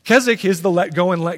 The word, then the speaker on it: Keswick: It's spelled Keswick, but it's pronounced Keswick.